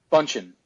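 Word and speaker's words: Bündchen: Bundchen.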